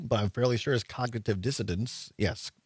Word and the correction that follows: dissonance: Dissonance. You still dissidents. Said, it still sounded like you said dissidents the second time.